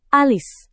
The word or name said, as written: alis